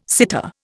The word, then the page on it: sitar: Indian